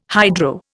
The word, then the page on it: hydro: Indian